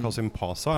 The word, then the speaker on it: Kasımpaşa: Uh, Kasim Pasa, er det, det er riktig uttalt? Ja. Ja.